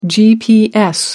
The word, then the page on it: gps: American English